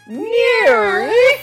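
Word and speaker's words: NIIRYF: Those are the letters for the name of the show, and it's pronounced Near.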